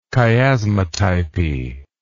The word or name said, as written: chiasmatype